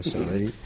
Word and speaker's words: Sabadell: Sí, jo volia preguntar, bueno, al conseller delegat del Banc Sabadell, Banc Sabadell, que la resta d'Espanya costa de pronunciar aquesta doble L, no? Sabadell.